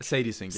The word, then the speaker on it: Sadie Sink: Sadie Sink, ja.